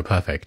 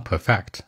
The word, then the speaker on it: perfect: 那这种完美就 perfect，注意发音啊！很多人愿意说成什么 perfect，往后靠是吧？往前走。